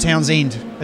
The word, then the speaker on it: Townshend: And it's a, uh, for, it's a silent H, so it's just Townsend.